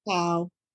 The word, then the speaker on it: cacao: I mispronouncing that? – How did you uh, pronounce it? Cacao. – Cacao. Cacao, okay.